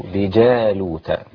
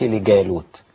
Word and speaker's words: لجالوت: ولما برزوا لجالوت، ما قلش لجالوت، الجيم هنا معطشة في القرآن، وبلاش الجيم البدعية ديت كما يقول شيخنا الشيخ عبد الحكيم.